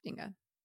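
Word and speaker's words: Dingga: The song and music video is called Dinga. Am I saying that right? I think so. – Yes, Dinga.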